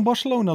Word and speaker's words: Barcelona: Dus stel dat Feyenoord kan Barcelona loten, zeg ik dat goed? – Ja.